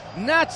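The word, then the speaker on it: Nagy: Nach Schiffer en el doble pivote, no se escribe así.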